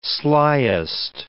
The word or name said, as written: slyest